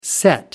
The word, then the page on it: set-to: American English